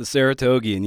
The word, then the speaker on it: Saratogian: As Sugi just alluded to, you got your start in local journalism, including covering horse racing at the Saratogian. Am I saying that right? – The Saratogian, yeah.